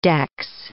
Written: dex

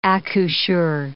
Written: accoucheur

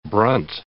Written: brunt

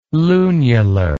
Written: lunular

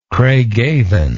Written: craigavon

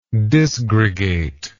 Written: disgregation

